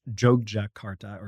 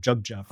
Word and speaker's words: Yogyakarta: In the center of Java is a city called, it's pronounced Yogyakarta or Jogja for short. – I worked so hard on it. – But it has a Y, so it's spelled like Yogyakarta. It's not Jakarta.